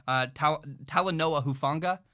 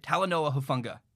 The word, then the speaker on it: Talanoa Hufanga: They also have a star future NFL safety, Talanoa Hufanga. I hope I said that right. Talanoa Hufanga.